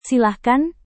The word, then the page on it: silahkan: Indonesian